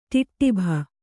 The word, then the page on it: ಟಿಟ್ಟಿಬ: ṭiṭṭiba